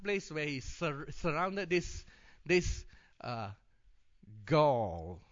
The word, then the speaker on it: Gaul: Gaul. Well, that's tough for me. Give it to me again. Goal. It's spelled as the football goal. Pronounced. I spell it G-A-U-L, but pronounced as football goal.